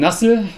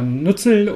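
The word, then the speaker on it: Nuzzel: Anlass war, der wirkliche Anlass war die Einstellung von Nassel oder Nützel oder ich glaube, Nassel ist die korrekte Aussprache im Englischen, ja.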